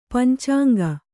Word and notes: ಪಂಚಾಂಗಿ: pancāŋgi

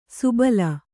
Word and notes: ಸುಬಲ: subala

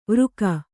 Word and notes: ವೃಕ: vřka

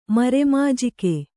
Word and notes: ಮರೆಮಾಜಿಕೆ: Kannada

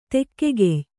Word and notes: ದೆಖ್ಖಾಯ: dekkhāya

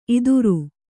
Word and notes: ಇತ್ತೆರ: ittera

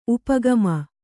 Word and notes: ಉಪಾಗಮ: upāgama

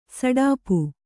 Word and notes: ಸಡಾಪು: saḍāpu

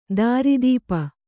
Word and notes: ದಾರಿದಪ್ಪು: dāridappu

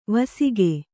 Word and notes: ವಾಸಿಗ: vāsiga

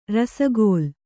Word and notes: ರಸಗುಲ್ಲಾ: rasagulla'

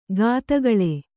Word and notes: ಘಾತಗಳೆ: Kannada; ghātagaḷe

